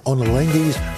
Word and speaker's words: Ottolenghi's: Do not miss Chef Yotam Ottolenghi's latest cookbook, Simple 2.